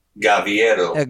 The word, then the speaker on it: Gaviero: Gaviero. – Gaviero. – Gaviero. – Gaviero. Well, look. – G-A-V-I-E-R-O.